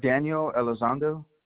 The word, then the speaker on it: Daniel Elizondo: Daniel Elizondo. – How do you spell it? – D-A-N-I-E-L, and then it's E-L-I-Z-O-N-D-O. – E-L-I-Z-O-N-D-O? – Yes, ma'am.